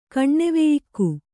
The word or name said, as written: ಕಣ್ಣೆವೆಯಿಕ್ಕು